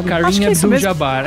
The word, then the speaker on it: Kareem Abdul-Jabbar: É assim que fala, gente, eu falei errado. – Kareem Abdul-Jabbar, mesmo...